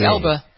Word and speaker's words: Elba: It's A-L-B-A. – Idris Elba. Yeah, Elba.